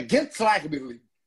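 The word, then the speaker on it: Gençlerbirliği: É, não, mas é, juro por Deus, eu ouvi turcos falando, mandei áudio para amigos turcos. É Gentlagberli, que é um clube turco, oh.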